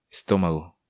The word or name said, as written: Estomago